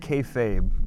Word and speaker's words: kayfabe: And you call it kayfabe, but I think it's kayfob. I don't know how to pronounce it. I'm the worst pronouncer in the world. – You said it right the first time.